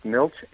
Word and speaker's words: Milch: Milch, M-I-L-C-H.